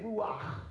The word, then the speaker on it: Ruach: That, just the way I said it, Ruach, because that's the pronunciation in the Hebrew.